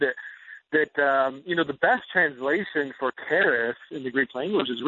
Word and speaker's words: charis: It's charis, yeah.